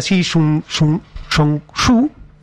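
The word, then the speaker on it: Xi Zhongshu (习仲勋): Era Xi Zhongshu, así se pronuncia, que elige estas tres zonas precisamente por esta cuestión relevante geopolítica.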